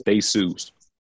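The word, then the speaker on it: Theseus: All right, so this if y'all ever want to look this up, this is called the ship of, I think it's pronounced Thesus, and this is the whole summary right here.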